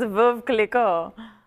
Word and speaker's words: Veuve Clicquot: It is Veuve Clicquot.